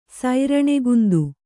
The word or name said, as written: ಸೂರಣಕಂದ